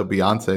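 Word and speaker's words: Beyoncé: It's Texas Hold'em by, I'm going to mispronounce as Beyonce. – No, you nailed it. Yeah, her name is Beyonce.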